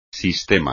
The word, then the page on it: sistema: espagnol